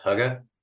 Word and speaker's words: hygge: And one of the concepts that she loved about it that the locals told her was this, I think it's pronounced hygge. Hygge, yes.